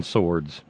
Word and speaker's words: sword: It doesn't appear that the people that voted for Trump understand that they've just fallen on their own swords. Fallen on them? They can't spell swords. Now here's some more of that hillbilly elitism. Not that they can't spell sword, they just pronounce the W in it.